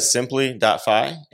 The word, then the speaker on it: Simpli.fi: Yeah, website is Simpli.fi, and I've been saying it wrong.